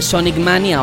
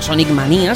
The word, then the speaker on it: Sonic Mania: El anuncio de Sonic Mania o Sonic Manía, según como lo pronunciéis, será uno de los puntos claves del programa de hoy.